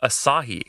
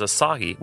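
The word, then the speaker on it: açaí: only recently has Asahi, which is spelled A-C-A-I, by the way, become even heard of outside of South America, not to mention available in any form.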